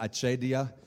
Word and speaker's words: acedia: When the monks were trying to pay attention in the Middle Ages, there's a Latin word, acedia, or they pronounce it acedia, which is when you just can't focus.